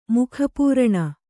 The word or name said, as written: ಮುಖಪೂರಣ